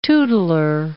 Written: tootler

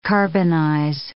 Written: carbonize

